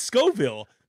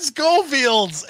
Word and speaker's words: Scoville: I don't know, but it's Scoville. Man, come on, dude. Let's go. Knocking out of the park already. – I said Schofield's.